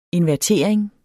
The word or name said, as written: invertering